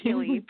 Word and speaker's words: Gilli: Although the SNL skit, Gilli, with that like sadistic little girl who causes problems, I don't know if you're familiar, but it's Kristen Wiig, that helps a lot with my pronunciation, with the other people's pronunciation, rather.